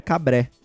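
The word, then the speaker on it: Cambrai: Ele falou sobre a pronúncia da cidade e na verdade se pronuncia Cabré. Por quê? O A com I lá no final, os caras falam Cabré.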